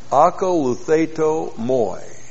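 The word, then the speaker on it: akaluthaito moi (ἀκολουθείτω μοι): We have akaluthaito moi. A-K-O-L-O-U-T-H E-I-T, long O-M-O-I. Akaluthaito moi.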